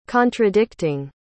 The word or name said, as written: contradicting